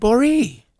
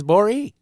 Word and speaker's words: Gborie: You nailed my name. – Thank you. – Good for you. – Well, I have a pronunciation guide right in front of me. – Oh, does it say boring with no N? – No. It says Boree. – How does it spell? – B-O-R-E hyphen capital E capital E.